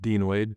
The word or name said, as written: Dean Wade